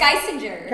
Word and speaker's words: Geissinger: We never talked about how to pronounce this. – Geisinger? – I think it's Geisinger.